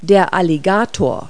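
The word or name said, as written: alligator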